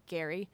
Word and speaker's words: Gerry: But along with George Mason was Elbridge Gerry, who is kind of claim to fame is the word gerrymandering, which we pronounce wrong because he pronounced it Gary.